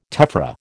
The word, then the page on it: tephra: American English